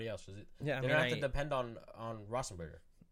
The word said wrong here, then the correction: Roethlisberger: Can I get the correct pronunciation of that? – A Roethlisberger? – Yeah, Roethlisberger.